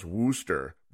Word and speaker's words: Worcester: If you noticed his little tag at the top of the screen, his name is pronounced Wooster, but it's spelled Worcester.